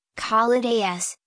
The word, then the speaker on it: Kalidas: The Servant of Kali, pronounced Kalidas, is a 1931 Indian Tamil language biographical film directed by H.M.